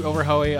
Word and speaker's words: Oberhoy: Rhymes with Chips Ahoy, as he told me. It's Steve Oberhoy of the Dirty Catfish Brass Band.